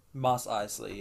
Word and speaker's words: Mos Eisley: Is it pronounced Mos Eisley? – Mos Eisley, yeah.